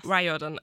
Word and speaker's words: Riordan: Ja, sagt euch der Begriff, das ist jetzt Englisch, also meine Aussprache, äh, aber Rick, I thought it was closer äh, Riordan. Also auf Deutsch, Rick, ich dachte, es wär, ne, wäre näher Riordan.